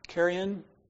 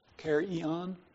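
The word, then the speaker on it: chairein: And I think it's pronounced charon. Charion, not like what crows eat, but charion.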